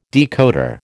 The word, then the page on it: decoder: American English